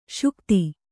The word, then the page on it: ಶಕ್ತ: śakta